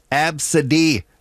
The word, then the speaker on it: ABCDE: On that list, there's ABCDE, which is pronounced Absidy.